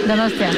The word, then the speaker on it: Donostia: How do I say it? Donostia. – Donostia.